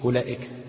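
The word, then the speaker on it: أولئك: ماشي، لو كان الحرف في وسط الكلمه يبقى كده الحرف ده ما بيتنطقش خالص لا وصلا ولا وقفا، زي ايه؟ اولئك. اولئك هتلاقي مرسومه في المصحف بايه؟ همزة وبعدين واو وبعدين لام وبعدين همزة على نبرة وبعدين كاف، صح كده؟ الواو هتلاقي عليها دايرة صغيرة كده، يبقى الواو دي ما بتنطقش لا وصلا ولا وقفا.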